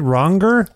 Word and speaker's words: Wronger: When I listened to this wonderful record, Wronger. Am I saying that correctly? Am I pronouncing that right? Wronger? – That's correct.